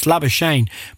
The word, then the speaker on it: Labuschagne: Is that how you say it? – Labuschagne.